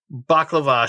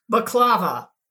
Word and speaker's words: baklava: So when she sees him now, this is post baklava sharing, which they pronounce what, boss? How do they pronounce that? – Baklava. – Yeah. Baklava. Yeah. It's closer to balaclava for some weird bizarro reason.